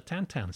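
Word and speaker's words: Tintin: You were hired by Steven Spielberg to work on the Tantan script. – So I know you pronounced it correctly.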